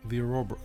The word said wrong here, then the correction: Ouroboros: why can I never pronounce it on the first try? The Ouroboros.